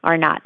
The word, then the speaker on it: R naught: Every virus has a number um, that we call R naught. It's like R sub zero. R naught is how it's pronounced.